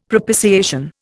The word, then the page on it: propitiation: Indian